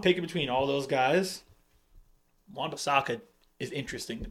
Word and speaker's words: Wan-Bissaka: Does it rhyme product. – With Schmon Schmishaka? – That was a terrible way to do that. But yes, it does rhyme with Schmon Schmishaka. It would be Wan Bissaka.